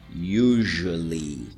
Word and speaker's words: usually: Usually. Cuidado com a pronúncia dessa aí, hein, gente? Usually. It's spelled U-S-U-A-L-L-Y. Usually.